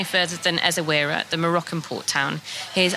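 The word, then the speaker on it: Essaouira: Writer, and we are going to chat about Ezawira in Morocco. So Ezawira, finally we've got the pronunciation right.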